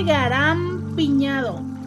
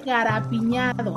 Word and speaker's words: garapiñado: No se dice garampiñado, se dice garapiñado. O sea, básicamente lo que tenemos que quitarle es la M.